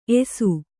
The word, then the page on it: ಏಸು: ēsu